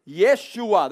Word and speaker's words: Yeshua: And she will bring forth a son, and you shall call his name Yeshua. That's how you say it in the Hebrew.